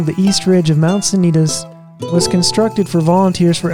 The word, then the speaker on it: Sanitas: I know several people who say Sanitas. And if you do research on the word, it does seem like that is likely to be the original pronunciation. Back when it was named after the sanatorium that was right there at the base of the mountain. So I think there's a lot of evidence that it was for a long time pronounced Sanitas. And now we say Sanitas, and, you know, it's just kind of too late to go back.